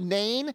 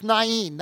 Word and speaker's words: Nain: Now, Nain is a little village, and I know you see the dot up there and you see it, and it looks like the word's Nain. It's not. It's Nain. That's how you say it. The word is Nain.